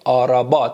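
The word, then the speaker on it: araba: مثل آرابا، آرابا. با من برید آرابا، تلفظ درست برید بچه ها، آرابا.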